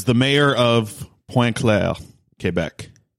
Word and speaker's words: Quebec: From Port Alberni to Pointe Claire, Quebec, or Pointe Claire, Quebec. – Wow. You even said Quebec. – Yeah, well, that's how you say it.